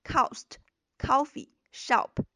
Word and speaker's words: cost coffee shop: 注意这三个单词的这个元音都是这个圆口的 o，这个圆口 o 在发音的时候大家要注意，嘴巴一定要是先小后大的，而不是先大后小，不要发成 cost coffee Shop，这样就不对了，而这往往是很多中国学生容易犯的错误。